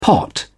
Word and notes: pot: British English

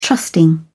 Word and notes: trusting: British English

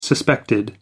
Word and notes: suspected: American English

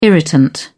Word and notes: irritant: British English

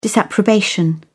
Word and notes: disapprobation: British English

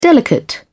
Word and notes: delicate: British English

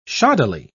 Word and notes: shoddily: British English

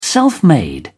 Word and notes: self-made: British English

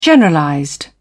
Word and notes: generalized: British English

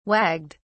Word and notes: wagged: British English

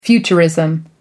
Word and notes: futurism: American English

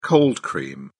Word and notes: cold cream: British English

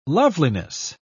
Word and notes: loveliness: British English

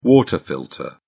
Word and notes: water filter: British English

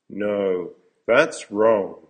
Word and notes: no, thats wrong: American English

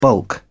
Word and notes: bulk: British English

